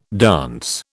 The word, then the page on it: dance: British English